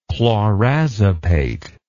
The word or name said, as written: clorazepate